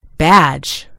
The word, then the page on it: badge: American English